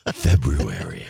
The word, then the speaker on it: February: Notice the correct pronunciation. Used to remind the listeners of that. February.